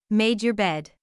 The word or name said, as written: made your bed